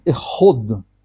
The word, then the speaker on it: эход: И мы говорим «эход». «Эход», да, здесь подчеркнуть нужно, что это не «хад», а «эход».